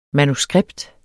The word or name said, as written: MANUSKRIPTERNES